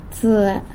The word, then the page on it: tsë: sanlucasquiavini